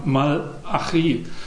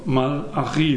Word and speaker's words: Malachi: Da heißt nämlich der Mann Malachi, Betonung am Ende, ohne E in der Mitte. Also Malachi, für die, die es ganz genau wissen wollen.